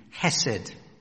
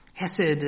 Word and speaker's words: Hesed: It's H-E-S-E-D, Hesed. Hesed. And it's difficult to translate because we don't have something like it in English.